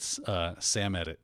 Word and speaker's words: Samedit: The vulnerability named Baron Samedit. – Nicely done. – Impacts most Linux distributions today. Did I pronounce that wrong? – Yeah, Samedit. Yeah, I would go with Samedit.